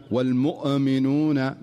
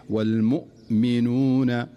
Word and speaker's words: والمؤمنون: ومن أمثلة ذلك في قوله تعالى والمؤمنون والمؤمنات مثلا، فتجد البعض يقول والمؤمنون والمؤمنون مؤ، هذه الزيادة في الهمزة أو الزيادة في نطق الهمزة والتكلف فيها هو الذي ينتج عنه هذا الخطأ، وهو قلقلة الهمزة، فتجده يقرأها فيقول والمؤمنون مؤ مؤمنون الصحيح والمؤمنون والمؤمنات والمؤمنون. شوف لاحظ الهمزة، لاحظ الهمزة أنها ساكنة دون قلقلة.